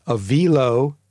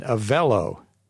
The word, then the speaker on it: Avelo: So I've heard it pronounced in the press Avilo, Avilo, and Avello. I think it's Avello. Max, yeah, what do you take? – That was my guess.